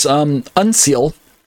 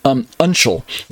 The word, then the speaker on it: uncial: uncial. And this writing, I believe I'm pronouncing it right, uncial, U-N-C-I-A-L, uncial, uncial, I believe it's probably what I should say, uncial.